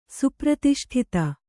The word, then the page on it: ಸುಪ್ರತಿಷ್ಠಿತೆ: supratiṣṭhite